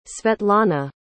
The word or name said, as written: svetlana